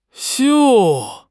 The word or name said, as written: 绣